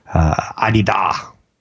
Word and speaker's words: Adidas: I learned the correct way to say it is Adidas.